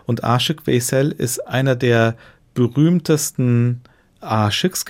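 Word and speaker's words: Aşık Veysel: Aschük Weissel, ist das richtig ausgesprochen? Aschek Weissel. – Mhm. Aschek Weisel. – Mhm. Weisel. Ja.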